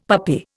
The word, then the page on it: puppy: Indian